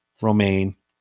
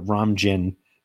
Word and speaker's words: Romijn: It's Rebecca Romaine Stamos. And okay. Steve had pronounced it Rebecca Romijn Stamos.